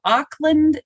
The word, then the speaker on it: Auckland: Thank you so much for joining me today, all the way from Auckland, New Zealand. Did I say that right? – Oh, you did.